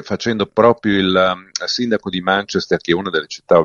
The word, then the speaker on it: Manchester: Ecco, hai così in questa maniera esaudito già la mia ulteriore richiesta di pronuncia corretta, perché Manchester e non Manchester, come dice qualcuno, per darsi un tono, evidentemente. – No, è Manchester, anche se sarebbe quasi con due accenti, Manchester.